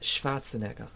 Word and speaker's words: Schwarzenegger: It's pronounced Schwarzenegger.